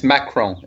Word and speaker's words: Macron: And it's Macron is the other one, not Marcon.